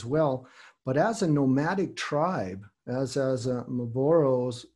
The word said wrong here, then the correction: Mbororo: Is it Maboro? Is that correct? – Mbororo. Yeah.